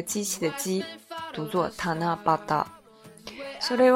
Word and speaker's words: 七夕: 为什么日本的这个七夕，它中文汉字写成七夕，但是它读作塔那巴达呢？